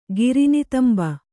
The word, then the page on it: ಗಿರಿನಿತಂಬ: kannada